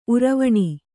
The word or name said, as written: ಉರುವಣಿ